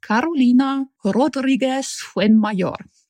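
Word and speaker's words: Carolina Rodríguez Fuenmayor: Und gemacht hat es eine Illustratorin aus Kolumbien, die heißt Carolina Rodriguez Fuenmayor. – Oh, das kannst du wunderschön aussprechen mit dem rollenden R.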